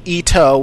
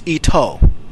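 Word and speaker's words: Ito (伊藤): The name is both written and pronounced differently to Ito, which has a short O sound meaning thread. So be sure clearly to pronounce the long O at the end, so it's Ito.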